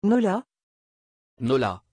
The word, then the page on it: Nola: French